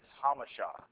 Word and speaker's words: Jamacha: And you come out to our place, and J-A-M-A-C-H-A, to some is Jamacha, and to us it's Hamasha. It's the Spanish pronunciation, and Mission Viejo, and San Diego, and all the rest of that, and you have yours.